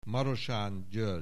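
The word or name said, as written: Marosán György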